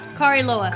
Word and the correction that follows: Kariloha: K-A-R-I-L-O-A-L-O-H-A. – Uh-huh. – Kariloha.